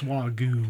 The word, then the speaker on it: Wagyu: Did I say that right? Wagyu? – It's Wagyu. – Wagyu? Yeah. Okay, maybe we'll talk about that.